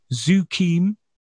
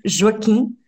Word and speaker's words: Joaquim: Joaquim del Almeida? Did I say it right? – No. but it was close. It's Joaquim.